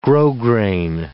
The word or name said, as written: grosgrain